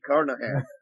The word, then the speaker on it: Carnohan: That's just like a giant leprechaun, man. Kyle Lee Carnohan.